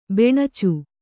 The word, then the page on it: ಬೆಣಚು: beṇacu